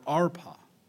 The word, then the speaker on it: Orpah: The name of one was Orpah, and the other name was Ruth, or Arpah, depending on how you want to pronounce it.